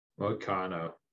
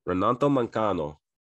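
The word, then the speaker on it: Moicano: How do you say his last name? – Mancano. – Renato Mancano. This was a catch weight at 160.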